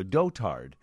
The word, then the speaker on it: dotard: Everything we've done online to get the correct pronunciation of D-O-T-A-R-D. – I also read somewhere that it rhymes with goat herd.